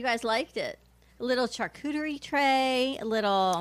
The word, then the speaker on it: charcuterie: Charcuterie. And it's so not on our point list, though. That's the other thing. – Charcuterie. – I don't understand. It's like Sharika. – A charcuterie tray.